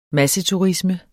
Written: masseturisme

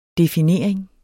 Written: definering

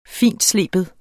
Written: fintsleben

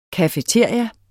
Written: cafeteria

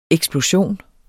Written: EKSPLOSIONENS

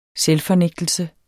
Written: selvfornægtelse

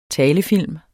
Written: TALEFILM

